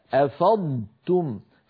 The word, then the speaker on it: أفضتم: ولا نقول مثلا افضتم في بعض اخواننا يحولون الصوت كله الى طاء مشدده فيقول افضتم ايش هذه افضتم لا يصح يا اخي أفاض يفيض أفضتم فعلينا أن ننطق ضادا صحيحة ساكنة ونتبعها بتاء مضمومة مرققة